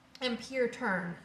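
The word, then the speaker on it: ampere-turn: So it doesn't say it's pronounced on par or on pair. It says it's pronounced ampere turn.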